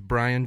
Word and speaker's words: Bryan: But as Ashley's looking through, right, my name is Bryan James Sherman. – Okay. – Spelled with a Y. – Right. – Okay. Which is weird. Most Bryans spell it with an I.